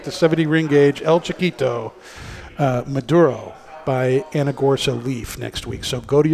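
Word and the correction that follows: Aganorsa Leaf: Agonorsa Leaf.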